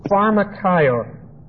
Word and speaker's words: pharmakeion: P-H-A-R-M-A-K-E-I-O-N. Pronounce that for me. Pharmakion.